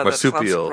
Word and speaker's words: marsupial: Marsupial. – Marsupial. Yeah, marsupial. – That's right. – Yeah, I think there's 68 marsupials in North America.